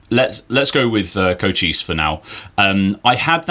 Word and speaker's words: Cochise: Cochise, yeah.